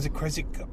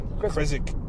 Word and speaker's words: Creswick: I don't think you pronounce the W in Creswick. I think I always say Creswick.